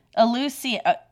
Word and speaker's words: Eleusinian: It's Eleusinian. – Eleusinian.